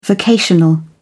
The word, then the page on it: vocational: British English